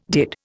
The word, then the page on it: did: Indian